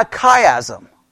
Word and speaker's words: chiasm: And a chiasm is spelled C-H-I-A-S-M.